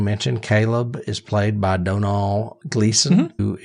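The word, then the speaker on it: Domhnall: And it is pronounced Donal. I looked that up because it's spelled with an M, and the M is silent. – Donal, yep.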